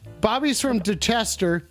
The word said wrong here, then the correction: Dorchester: You're fucking saying it wrong, dude. It's fucking Dorchester. – Dorchester? Is it dark? – Dorchester, dude.